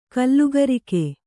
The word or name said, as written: ಕಲಿಕಾರಕ